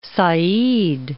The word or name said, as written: Port Said